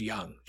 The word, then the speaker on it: Jung: It's Young. Jace Young. – Yep, Jace Young. – Young? – Yep. – Yeah, that's right. Okay, it's J-U-N-G, so forgive me if you're like, how could you not pronounce Just roll with me. – One of our student reporters from the campus newspaper still says Jung.